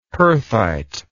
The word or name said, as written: perthite